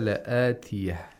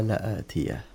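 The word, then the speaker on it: لَآتِيَةٌ: So this is why we pronounce it sa'ata atiyah. – As-sa'ata la'atiyah. – As-sa'ata la'atiyah.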